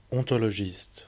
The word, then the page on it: ontologiste: /ɔ̃.tɔ.lɔ.ʒist/